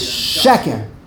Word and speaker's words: sheker: The proper way to say sheker is sheker. When there are two segels, whenever you have two segels, b'li yoytze min haklal, the emphasis is on the first, like melech, Purim, the Megillah.